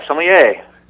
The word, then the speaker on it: sommelier: Is an advanced sommelier. Did I pronounce that properly? – Very good. Yeah, sommelier.